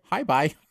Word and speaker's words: Hebei: Well, this particular one is in, and I'm going to butcher this, but I think it's Taihong Mountain in Hebei, China. I don't know how to... – Hebei. – It's Hebei.